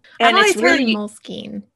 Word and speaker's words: Moleskine: Side note, did you know that it's actually supposed to be pronounced Moleskine? – No, I did not know that.